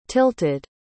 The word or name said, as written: tilted